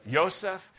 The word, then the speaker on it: Yosef: In Hebrew, how do you say Joseph? Yosef.